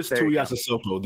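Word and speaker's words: Tuiasosopo: Tuiasosbo? Did I get that right? – Marquis Tuiasosbo. There we go.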